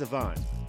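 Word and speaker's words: Siobhan: Sibobhan. But it's pronounced Sivan.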